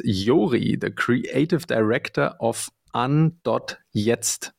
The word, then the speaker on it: an.jetzt: Um, the company name is actually An, so it's German for An, switching something on. So yes, An.jetzt is the right answer.